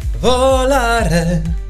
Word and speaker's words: Volare: Wie zum Beispiel, Volare. So ist es ja richtig.